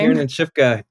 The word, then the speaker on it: Kiernan Shipka: played by Kiernan Shipka. Am I butchering her name? – Yeah, Kiernan Shipka.